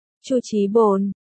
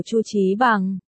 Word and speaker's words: Chu Chí Bằng: Chu Chí Bồn, cô gái nghiêm túc lặp lại cách phát âm tên của Chu Chí Bằng. Chú thích, từ, bằng, phiên âm là pén. Do Minako không phát âm được âm ân, nên đọc thành pén, là từ bồn.